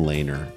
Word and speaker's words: Lehner: To win an award, and I want to tell you about New York Islander goalie Robin Lehner, and I don't even know if I'm pronouncing that right. You are.